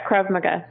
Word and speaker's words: Krav Maga: Krav Maga.